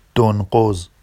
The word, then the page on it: donɢoz: Vafsi